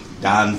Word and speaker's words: Don: Jody Don Frazier, J-O-D-Y-D-O-N, Frazier, F-R-A-S-H-E-R.